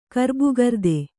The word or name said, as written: ಗರ್ಭೀಕೃತ